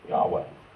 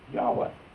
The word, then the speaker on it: Yahweh: They said that actually, it looks as though the name, which is this mystery, was probably pronounced Yahweh. That's, the E has sort of the effect of a long. Okay. So, Yahweh.